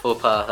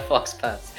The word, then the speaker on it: faux pas: I don't know how you pronounce it. Is it French? Faux pas? Is that how it is? I don't care. – I say faux pas. – I'm bad with... faux pas, that's how it is. I called it a fox pass.